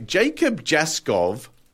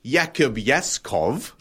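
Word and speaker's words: Jakub Jaskov: Fog of Love by Jacob Jaskov or Jakub Yaskov, depending on your pronunciation.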